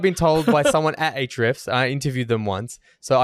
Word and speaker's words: Ahrefs: One thing I'll say, SEMrush does some things better than Ahrefs, and Ahrefs does some, and that's how you say it, by the way. That's how I've been told you're supposed to say Ahrefs. – Oh, is it? – Not Rfs.